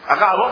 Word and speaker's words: Agabo: ¿Así se pronuncia Agabo? ¿No tiene acento? No puede ser Agabo, ¿o sí? Agabo, muy bien, Agabo.